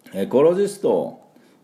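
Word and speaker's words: ecologist: ですから今日はこのエコロジストを、ちゃんとした発音で、いこうかなと思いますが、でまずはこのエコはじゃなくてエコですね。エコ、エコロジスト、エコロジスト、エコのところに、アクセントがあります。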